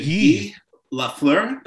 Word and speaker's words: Guy Lafleur: Okay, well, first that he pronounces it Guy Lafleur.